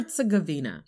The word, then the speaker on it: Herzegovina: Herzegovina.